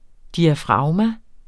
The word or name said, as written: diafragma